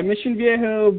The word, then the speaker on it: Mission Viejo: And so you, growing up in, how do you pronounce it? Is it Mission Viejo? – Yeah, Mission Viejo, but, you know, after that I had to move.